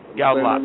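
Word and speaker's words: Goudelock: Andrew Goldlock or Goldlock? – Goldlock. – Right? Goldlock? – Andrew Goldlock.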